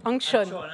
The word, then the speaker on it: Antxón: Did I uh, j'ai bien prononcé ton prénom? Oui. – Angshon. Angshon. Angshon.